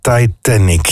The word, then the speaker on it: Titanic: Helemaal Hollands, de Titanic. Zeg ik dat goed? Ja, Titanic. Ja, klopt. Ja, dat is de eerste keer dat ik hem aankondig en afkondig.